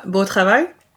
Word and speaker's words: Beau Travail: Claire Denis says, beau travail.